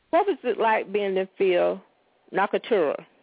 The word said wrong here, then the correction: Nocturna: The correct pronunciation is Nocturna, and it's taken from the word nocturnal, meaning of the night.